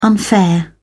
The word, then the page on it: unfair: British English